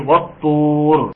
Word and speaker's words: الطور: مثل كلمة الطور والطور، ما بقولش والطور، لا بقولش كده، انا بقول ايه؟ والطور. يبقى دي لام شمسية لا تنطق، ودي لام قمرية تنطق.